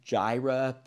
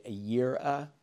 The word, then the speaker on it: Yireh: And that word Jireh, provide, is probably better pronounced Yireh. That's the preferred pronunciation.